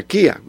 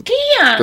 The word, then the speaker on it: Chía: ya tú eres, pero eh, eh, era original era Kia, o sea, lo pronuncian. Kia.